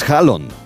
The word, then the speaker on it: Haaland: escandinavo, en el que hemos aprendido, por ejemplo, a decir el nombre real de Erling Haaland, que es Hallon. La doble A en los idiomas escandinavos se pronuncia como una O.